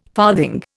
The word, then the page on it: farthing: Indian